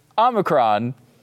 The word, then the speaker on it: Omicron: Number 1, how do you pronounce it? Now, I know I want to say Omicron or Omicron every single time, but it's not. It's Omicron. Omicron. Now, I have heard that there is some variance allowed there. You can go Omicron, which looks like kind of a Transformers pronunciation, or Omicron. I guess you could take either one of those. One thing you cannot do is call it Omicron, because it's not Omicron, right?